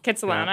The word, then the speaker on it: Kitsilano: I guess I went to like the Kitsilano Beach yesterday. Am I saying that right? – Kitsilano.